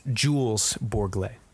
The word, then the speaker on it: Jules Bourglay: I think I just got his name wrong twice, by the way. It's Jules Bourglay.